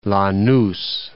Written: Lanus

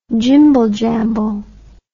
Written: jimble jamble

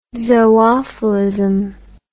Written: zoophilism

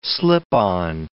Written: slip-on

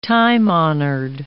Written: time-honored

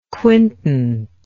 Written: quintant

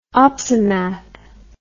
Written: opsimath

